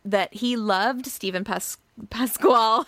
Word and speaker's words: Pasquale: Uh, it featured Sutton Foster as Queenie, Steven Pascal, Pasquale. I never know how to say it's, his it's last pa- name. – Uh, Pascal? – Pasquale. They say Pasquale. – Pasquale. Okay, I'm going to hope that's right.